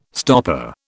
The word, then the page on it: stopper: British English